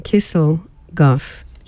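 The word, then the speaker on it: Kisselgof: So I'm going to go to something now that's from the Kligelgorf. I keep saying it wrong. Kissel Goff Archive.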